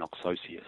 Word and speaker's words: Oxossi: For instance, Operation Oxossi, that's how I pronounce it, Operation Oxossi, which was a Brazilian operation, and that occurred over the last 2 years.